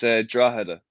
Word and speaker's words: Drogheda: So, Drogheda, yeah? Is that pretty much how you pronounce it? – It's Drogheda. – Drogheda, right, okay.